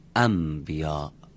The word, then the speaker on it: انبیا: بعد بگیم انبیا. ما تو فارسی هم اینطوری میگیم، شنبه رو میگیم شنبه، پنبه رو میگیم پنبه، در برای اینکه راحت تر بشه تلفظ اینطوری تلفظ میکنیم.